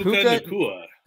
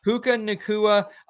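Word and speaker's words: Puka Nacua: And then Puka Nuku. Nakua. Can you help me pronounce Nakua. This? – Puka Nakua. – Puka Nakua, another fun pronunciation coming to the NFL sometime in the near future.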